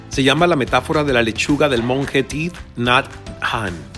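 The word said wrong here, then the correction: Thich Nhat Hanh: teeth Not Han. Creo que esta vez, esta vez le atiné a pronunciarlo correctamente.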